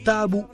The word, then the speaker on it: tabu: È lei l'autrice della voce Tabu linguistico, secondo la pronuncia da lei stessa consigliata, nell'enciclopedia dell'italiano Treccani.